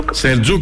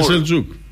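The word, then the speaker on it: Selçuk: Σελτζούκ. Σελκούκ. Ναι, Σελτζούκ, καλά το λες, καλά ναι. Το λες. Με C είναι, αλλά προφέρεται Σελτζούκ.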